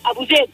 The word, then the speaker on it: Abouzeid: Muy bien, pues eh, Dina Abucet, ¿cómo se dice tu apellido? – Abuzet, en árabe.